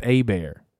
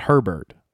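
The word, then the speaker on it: Hebert: It's pronounced A-Bear, not Herbert.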